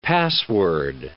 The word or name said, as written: password